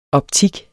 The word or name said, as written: optik